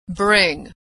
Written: bring

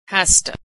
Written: hasta

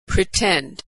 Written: pretend

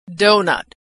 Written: doughnut

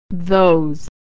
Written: those

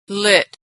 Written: lit

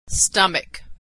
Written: stomach

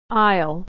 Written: aisle

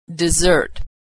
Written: dessert

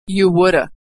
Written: you woulda

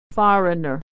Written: foreigner

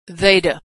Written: they'd have

